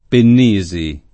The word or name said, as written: Pennisi